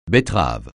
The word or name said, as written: betterave